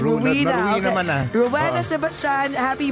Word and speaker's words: Rowena: Rowena. No, dito kasi sa US po, ang Rowena is not Rowena. We pronounce it as Rowena.